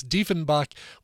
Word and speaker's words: Diefenbach: Diefenbach, I guess you pronounce the long version of the second vowel when you're doing German. It's Diefenbach with a cover of Policy of Truth right before that.